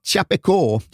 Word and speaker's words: Chapecó: Ska vi börja med att berätta var Chapeco ligger någonstans? – Ja, Chapecó, som jag tror betoningen med, ja, ligger.